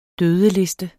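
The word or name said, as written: dødeliste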